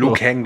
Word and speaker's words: Liu Kang: Die furchtlosen Kämpfer des Gottes Lord Raiden und der Prinzessin Kitana, Hongkong-Fighter Lu Zhang Habe ich das jetzt, oder wie wird er richtig ausgesprochen? – Liu Kang, glaube ich, wird er ausgesprochen.